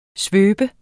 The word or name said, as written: svøbe